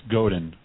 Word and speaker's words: Godin: And you were able to really pull off a coup and get Seth, do you pronounce it Godin or Godin? I've heard it pronounced both ways. – You know, the good news is it's Godin, because Godin would just be awkward.